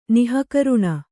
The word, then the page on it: ನಿಃಕರುಣಿ: nih karuṇi